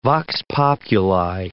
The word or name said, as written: vox populi